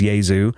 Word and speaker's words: Jesu: So if you've never heard Yaesu before, like I say, J-E-S-U, it is pronounced Yaesu.